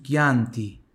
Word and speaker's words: Chianti: CHI são de Ki, por isso Chianti.